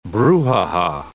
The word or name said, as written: brouhaha